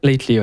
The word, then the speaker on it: Lately: On le prononce comme ça ? – Lately, ouais. – Ah, nickel.